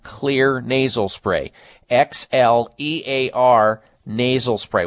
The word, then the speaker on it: Xlear: And Clear, is the reason it's spelled X-L-E-A-R is the X stands for xylitol, which has been proven to be one of the best nasal cleansers that you can use.